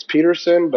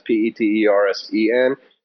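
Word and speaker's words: Petersen: My last name is spelled the Danish way. So it's Peterson, but it's with all E's, no O's. That's Peterson. If you type in Austin Peterson, you're going to find a lot.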